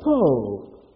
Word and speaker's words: tov: The word good here, though, is the Hebrew word tov. T-O-V, or some spell it as T-O-W-B. Tov.